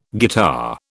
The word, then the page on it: guitar: British English